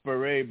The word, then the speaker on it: barrette: It's pronounced beret, but it's spelt barrette.